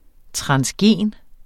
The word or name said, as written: transgen